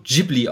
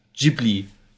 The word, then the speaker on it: Ghibli: Ich glaube, es wird Studio Ghibli ausgesprochen, wie mit einem G, G-I, Ghibli.